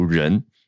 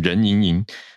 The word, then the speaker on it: 任盈盈: 因为人民日报的人嘛，他就取一个谐音，可是写起来其实是呃，以前那个什么，以前金庸金庸金庸里面有一个任盈盈，对不对？可是就是那个任何的任啊，只是在中国习惯读音应该是读人。啊，就是你看他会变成任盈盈。